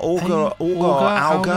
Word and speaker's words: Auger: A-U-G-E-R? Auger? I'd say Auger, Lucy. Auger, yeah, Lucy, I hope we got Auger.